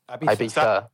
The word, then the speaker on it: Ibiza: But that's because it's like the same with, I always looked at people funny when they called Ibiza, because apparently it's pronounced the way you pronounce a Z in, is it Spanish? Wait. Or is Z is like TH, is th, Ibiza. Ibiza.